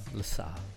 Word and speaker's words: LaSalle: I use the French pronunciation. LaSalle.